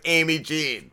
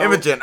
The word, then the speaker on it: Imogen: And I wanted to ask, too, like, does Frankie, because Frankie connected us, does he pronounce your name wrong? Because anytime he talks about you, it sounds like Amy Jean. And I'm like, how do I say her name? – Frankie pronounces everything a little funny. my name is Imogen. So. – Imogen, okay. Imogen.